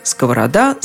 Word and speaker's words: сковорода: Ударение на последнем слоге. Сковорода, сковороды, сковороде, сковороду.